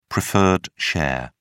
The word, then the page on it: preferred share: British English